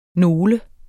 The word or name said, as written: nole